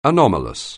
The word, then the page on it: anomalous: anglais